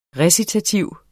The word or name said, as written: recitativ